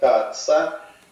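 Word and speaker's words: CAATSA: Мы знаем, что США ввели а, специальный закон, он по аббревиатуре читается как КАЦА,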